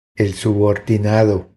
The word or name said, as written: El subordinado